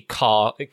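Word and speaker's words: Ka: It's a very old, tired, rusty car, K-A, car. That's how you pronounce it, isn't it? Car. Isn't that how you pronounce that brand? – Ford K-A.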